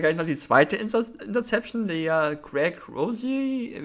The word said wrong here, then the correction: Rousseau: Rousseau, Gregory Rousseau.